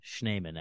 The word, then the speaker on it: Schneeman: It was for context for everyone, uh, my real last name is Schneeman, Schneeman actually, but no one ever says it right. Mm-hmm. And uh, no one could ever spell it right, say it right, anything.